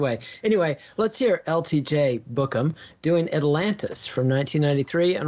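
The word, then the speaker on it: Bukem: Tell me how to pronounce this guy's name before I butcher it. – LTJ Bookham. – Book them. All right, that's what I was going to try.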